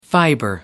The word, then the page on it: fibre: American English